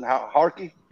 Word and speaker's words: Harki: I don't know about how you pronounce it, Harki? – Yeah.